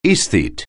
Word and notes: aesthete: anglais

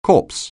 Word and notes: corpse: anglais

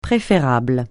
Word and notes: préférable: francais

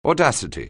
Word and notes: audacity: anglais

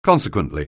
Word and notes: consequently: anglais

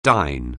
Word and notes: dine: anglais

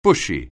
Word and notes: bushy: anglais

